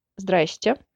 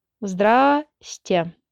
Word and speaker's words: здрасте: A lot of consonants in that one, so you could try a less formal sounding but still recognizable здрасте. Здрасте. Здрасте. That's not an official short form, that's just how a lot of Russian speaking people pronounce it.